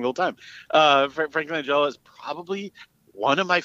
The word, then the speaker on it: Langella: But at the time, like, I, honestly, I, I, will say this much: Frank Langella is one of my, and I, I would say Langella. I don't. I guess I'm wrong. – I, uh, I think it is Langella.